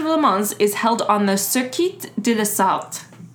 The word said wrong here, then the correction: Sarthe: No, that's not it. Sarthe. I put the R on the wrong part of the syllable.